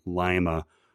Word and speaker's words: Lima: When the deputies are eavesdropping on a USAF communications via radio, meaning the Air Force, the airman can be heard saying L-I-M-A. However, he pronounces it the wrong way, saying Lima rather than Lima, which is the correct pronunciation.